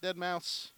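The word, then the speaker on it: deadmau5: You're wearing the light-up Dead Mouse. Am I saying that right? Dead Mouse? – Yeah, yeah.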